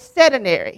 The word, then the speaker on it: sedentary: I heard something on TV the other day, and somebody's asked me what this word means, and I can't pronounce it right, but it's sedentary. Is that how you pronounce it? Yeah, that's it.